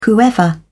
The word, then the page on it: whoever: British English